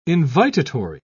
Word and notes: invitatory: British English

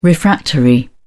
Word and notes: refractory: British English

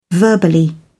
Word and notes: verbally: British English

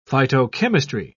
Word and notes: phytochemistry: British English

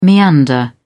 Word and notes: meander: British English

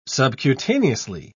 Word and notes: subcutaneously: British English

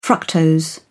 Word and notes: fructose: British English

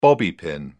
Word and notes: bobby pin: British English

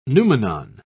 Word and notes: noumenon: British English

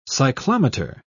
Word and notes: cyclometer: British English

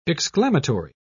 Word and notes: exclamatory: British English

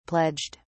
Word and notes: pledged: British English

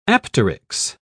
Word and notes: apteryx: British English

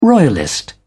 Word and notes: royalist: British English

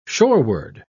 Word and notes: shoreward: British English

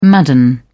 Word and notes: madden: British English

